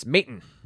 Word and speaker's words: Maton: Nick, I don't know if it's Maton or Maton.